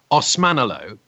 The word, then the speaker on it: Osmanoglu: It's Naz Osmanoglu, but my full name is actually Prince Nazim Ziaeddin Nazim Osmanoglu.